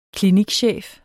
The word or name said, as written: klinikchef